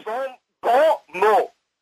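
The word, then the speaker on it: bons mots: How do you pronounce that word? Bon Mots? – Bon Mots. – Oh, you're saying it in French, okay?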